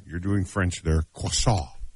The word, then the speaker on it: croissant: K-R-C-R-O-I-S-S-A-N-T. – Croissant. – Croissant. Yeah, I go, croissant? – Not crescent.